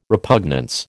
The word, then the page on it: repugnance: American English